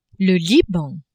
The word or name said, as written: Liban